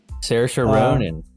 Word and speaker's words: Saoirse Ronan: And then I'm going to butcher her name, but Saoirse Ronan? – Saoirse Ronan.